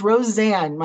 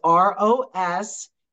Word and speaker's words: Rosanne: It's Roseanne. My mother thought it was a good idea to give me the European spelling. So let me spell it for you. It's Rosanne.